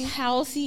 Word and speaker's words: Halsey: Halsey, yeah, it is Halsey.